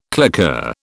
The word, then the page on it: claqueur: British English